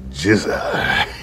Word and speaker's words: GZA: My guest this week is GZA.